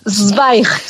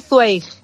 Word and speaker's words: Zweig: Estuviste con el 24 horas para la vida de una mujer, la obra de Stefan Zweig, ¿cómo se dice? – Zweig. También se puede decir Zweig, vale. – Zweig, como tú quieras.